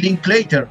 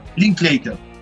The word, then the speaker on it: Linklater: te recomiendo Despertando la vida del director Richard Inskester, encima un apellido que nadie puede pronunciar en la vida. Eh, Linklater, Linklater.